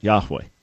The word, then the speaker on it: Yahweh: That, according to everything we understand, linguistically and textually and many other reasons, is the best way to pronounce God's name as it was given to the people of Israel, Yahweh.